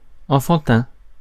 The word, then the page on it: enfantin: France; /ɑ̃.fɑ̃.tɛ̃/